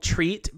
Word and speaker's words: Treet: Did you know the off-brand is called Treat, but it's spelled T-R-E-E-T? – Okay.